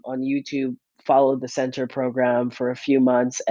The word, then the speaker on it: Centr: All right, the second and last app that I will promote here on this channel is going to be one called Center by Chris Hemsworth, aka Thor. It is spelled C-E-N-T-R.